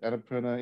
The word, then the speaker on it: Annapurna: Annapurna? – Yeah.